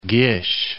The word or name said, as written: Gish